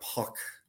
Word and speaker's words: Pac: We used to have Puck, is that how you pronounce his name? Yep. P-A-C.